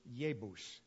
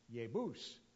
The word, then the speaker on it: Jebus: So when you see the J in Hebrew, it's almost always pronounced like a Y. It is Jebus, Jebus.